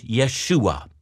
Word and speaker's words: Yeshua: The Jewish pronunciation, the name by which he was called, was Yeshua, which means Yahweh, or the God of Israel, saves.